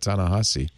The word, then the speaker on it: Ta-Nehisi: Baratunde finally told me how to say that, Ta-Nehisi, which is not how it's spelled.